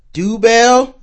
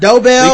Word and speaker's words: Dobell: So for the second time on the show, but first time live in person, we have mentalist Eric Dobell, did I pronounce that right? – Yeah, it's actually Dobell. – Dobell.